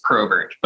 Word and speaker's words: Probert: It is Probert, but I have gotten all of those at different points in my life.